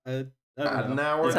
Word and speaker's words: Adenauer: So next, Quest and Adenauer, which I'm trying to pronounce that right. – Adenauer, the German right. – fellow. – Adenauer, that's her father.